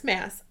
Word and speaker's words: Maas: Maas.